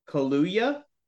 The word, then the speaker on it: Kaluuya: Spider-Punk, aka Hobie Brown, will be played by Daniel Kaluuya.